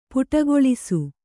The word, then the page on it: ಪುಟಗೊಳಿಸು: puṭagoḷisu